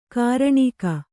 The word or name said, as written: ಘೂರ್ಣಕ